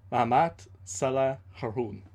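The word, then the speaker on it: Mahamat Saleh Haroun: Here, I'm going to try to fix the pronunciation because I found out that last time I was doing it a little bit off. I was close, but it's Mahamat Salah Harun.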